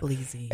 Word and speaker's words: Bleezy: Buffy, remind me, your last name rhymes with Breezy, right? – That's right. – Buffy Bleezy. – Bleezy.